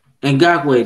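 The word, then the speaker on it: Ngakoue: I mean, you got Nkogwu. I think that's his name. I probably butchered it. I'm sorry. But Ngakwe, I know he was really – what's his name? – I think it's Ngakwe. – Ngakwe, there we go, there we go.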